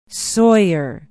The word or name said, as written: sawyer